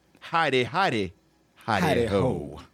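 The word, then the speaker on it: Hi De Ho: I believe it is pronounced Heidi Ho.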